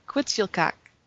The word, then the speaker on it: Quetzalcoatl: Quetzalcoatl. yeah, Quetzalcoatl.